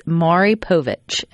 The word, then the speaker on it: Maury Povich: No, but one of those for the last, I guess, 31 years was Maury Povich. Am I saying that correctly? – Oh, yeah.